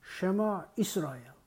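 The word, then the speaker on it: 쉐마 이스라엘 (שמע ישראל): 히브리어 발음으로 하자면 쉐마 이스라엘. 이스라엘의 하나님은 말씀으로 자신을 드러내십니다.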